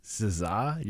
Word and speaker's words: SZA: S-Z-A? SZA? You ever hear that one? – S-C-A. – Yeah. – S-C-A.